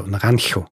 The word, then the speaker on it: Naranjo: Jemand hat mich darauf hingewiesen, dass die Stadt, in der Lady Six Sky zugegen war, Naranjo, dass ich das zu stimmhaft ausgesprochen habe. Mhm. – Ich muss es aussprechen, wie das Beispiel, das gegeben wurde, war Aachen. Also Naranjo. Naranjo.